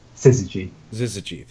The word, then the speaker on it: Syzygy: I always forget how to pronounce this. The Zyski. Sorry, go ahead. – Syzygy, thank you.